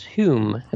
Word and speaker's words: Hume: You talked about David Holm, about Ted Phillips, I believe, and Joseph of Cupertino. – Right. – And the middle one is Daniel it's pronounced Hume. It looks like home, but it's Hume.